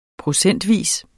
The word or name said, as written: procentvis